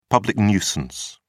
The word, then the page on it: public nuisance: British English